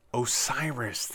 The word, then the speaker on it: OSIRIS: Want to be able to talk about the what happened with NASA's, oh, how do you pronounce this? Hang on. Basically, they sent a probe to an asteroid to be able to get a sample from said probe. – OSIRIS, that's how it is.